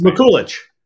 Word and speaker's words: Mikulić: It's Mikulić.